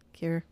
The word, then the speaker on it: Kir: K-I-R. Cur.